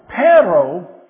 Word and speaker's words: perro: All right, and you got to kind of roll your R's. That's why I'm getting Nacho to do it, because I can't really do it. Okay, so perro, P-E-R-R-O, doesn't sound anything like dog. D-O-G, perro.